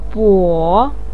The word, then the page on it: bo3: pinyin